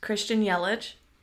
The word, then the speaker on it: Christian Yelich: Christian Yelich.